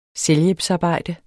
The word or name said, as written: selvhjælpsarbejde